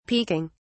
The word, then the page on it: peeking: British English